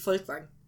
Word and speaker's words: Folkvang: Der findes jo ikke mange beretninger om livet som kriger i, i Folkvang ja, det hedder hendes hus. – Ja, ja, jeg valgte at udtale det tysk folkvang.